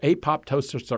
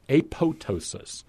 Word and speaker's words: apoptosis: In fact, it's either apoptosis or apoptosis. Whether you pronounce the other P depends on whether you're from a group that believes that pterodactyl is pterodactyl or whether you're from a group that believes that helicopter is helicotter.